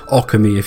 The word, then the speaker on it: Okami: I think the correct pronunciation is Okami if you're being a prick.